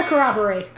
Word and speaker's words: Corroboree: Oh, it is the Corroboree. – Okay. – Oh, I must be thinking of a different weird Australian pronunciation word.